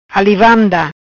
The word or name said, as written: Ali vanda